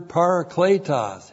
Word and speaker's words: parakletos: P-A-R-A-K-L, long E-T-O-S, parakletos.